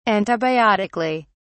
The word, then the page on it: antibiotically: British English